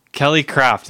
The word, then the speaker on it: Kelly Kraft: And her name rhymes with Belly Draft. – Ah, why are you being conceited on a... – Kelly Kraft is running for governor.